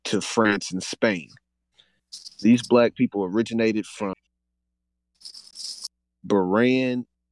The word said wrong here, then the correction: Iberian: I didn't see that eye right there. I thought it was a parenthesis. Iberian Peninsula, who were actually the Moors, and are a fragment of the old Spanish population.